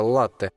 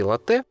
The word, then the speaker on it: латте: Если сделать заказ с правильным ударением, он обойдется гостю дешевле, а если, например, вместо «латте» произнести «латте», заплатить за него придется немного больше.